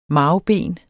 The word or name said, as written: MARVBEN